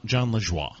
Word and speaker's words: Lajoie: But anyway, and John Liejo from The League. It's John Lajoie.